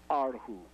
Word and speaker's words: erhu: The way you should pronounce it is arhu.